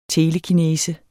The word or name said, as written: telekinese